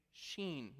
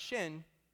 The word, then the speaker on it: Shin: And the first symbol, you can go to the next slide, is everyone say sheen, not shin. Sh- say it like you have an accent.